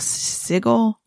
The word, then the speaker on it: sigil: but it's S-I-G-I-L, sigil? – A sigil? – Sigil, whatever, however you say it. Sigil, there you go.